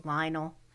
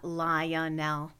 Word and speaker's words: Lionel: Of course, my cat, Lionel, one of my favorite names, starts with L-I and it has, well, it could be three syllables, but normally it's two. It's Lionel. It's not Lionel.